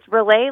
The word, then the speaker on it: Relay: It's Relay Like Chevrolet.